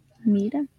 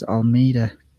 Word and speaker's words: Almeida: Almeida. – This is Almeida.